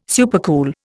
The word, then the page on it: supercool: Indian